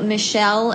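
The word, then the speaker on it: Michele: Yeah, so I'm on Twitter and Instagram at Rachel Michelle, and that's M-I-C-H-E-L-E. 4-3. I know. My middle name is spelled weird.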